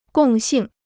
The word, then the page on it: 共性: gòng xìng